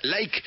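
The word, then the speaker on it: Layk: Like, es esa comunidad, se escribe L-A-Y-K, L-A-Y-K.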